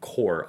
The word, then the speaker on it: cour: This is the first episode of the second core of the season. – Yeah, I've never heard that before. That's a like. – I swear it's a new term. Yeah. Like it's spelled C-O. Yeah, C-O-U-R.